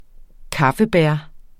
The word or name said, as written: kaffebær